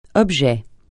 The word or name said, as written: objet